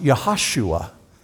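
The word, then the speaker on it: Yehoshua: In the Old Testament, it's pronounced Yahashua.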